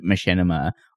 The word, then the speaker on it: machinima: I think it's machinima.